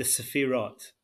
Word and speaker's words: Sefirot: So I had a question about the Sefirot. Can you say it again for me? I want to try and say it right. – So we'll talk about them in the plural. So it's the Sefirot.